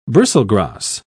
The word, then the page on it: bristlegrass: British English